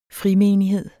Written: frimenighed

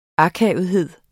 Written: akavethed

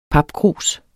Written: papkrus

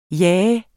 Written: jah

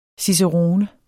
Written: cicerone

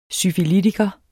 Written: syfilitiker

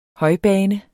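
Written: HØJBANE

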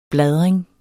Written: bladring

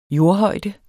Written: jordhøjde

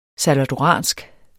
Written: salvadoransk

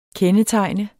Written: KENDETEGNET

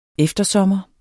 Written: eftersommer

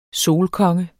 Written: solkonge

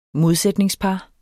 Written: modsætningspar

